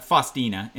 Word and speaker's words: Faustina: His wife, Faustina, am I pronouncing that correctly? – Yeah, sure, yep.